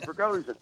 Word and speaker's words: Prigozhin: It's only spelled Prigozhin. I mean, you know, you can say Skwitiri, right? You can say Prigozhin.